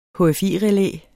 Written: HFI-relæ

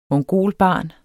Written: mongolbarn

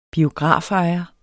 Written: biografejer

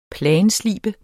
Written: planslibe

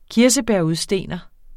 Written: kirsebærudstener

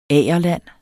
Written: agerland

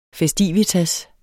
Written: FESTIVITASSEN

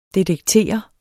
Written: detektere

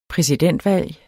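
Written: præsidentvalg

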